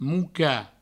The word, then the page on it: mocar: mouca